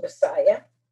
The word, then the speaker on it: MSIA: The name of the group was MSIA, which could also be pronounced Messiah.